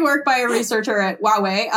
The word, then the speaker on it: Huawei: Huawei, is that how you say it? – Huawei? – Thank you. Wow, I was way off.